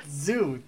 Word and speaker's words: Tzu: Zoo, T-Z-U or whatever.